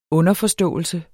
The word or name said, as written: underforståelse